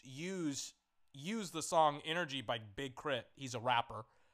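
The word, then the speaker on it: Big K.R.I.T.: Not Big Crip, as in like a Crip, as in sea walking. Big Crit, as in critical energy, as in like critical. But it's spelled K-R-I-T.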